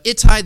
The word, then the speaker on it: Ittai: His name is Ittai.